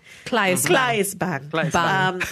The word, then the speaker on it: Claes Bang: Claes Bang.